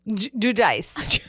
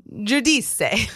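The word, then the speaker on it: Giudice: Judice. Judice. – Gudice. Judice. Yeah, that is a hard one. Judice. – Yeah. – I will shake my head when I do Sophia. I Judice.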